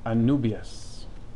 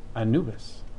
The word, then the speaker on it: Anubias: So we're going to talk about Anubias, which is often pronounced inexplicably Anubis, which is actually the jackal god of ancient Egypt.